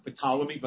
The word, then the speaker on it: Ptolemy: Now, Ptolemy is spelled with a P, like Ptolemy, but the P is silent.